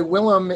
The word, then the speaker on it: Willem: He started to go by Willem in college, which is the Dutch pronunciation of his name, I believe.